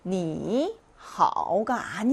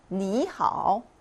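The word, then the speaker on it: 니하오 (你好): 삼성삼성이 같이 오면 이성삼성으로 발음했죠. 그래서 얘는 니하오가 아니라 니하오. 니하오 이렇게 발음합니다.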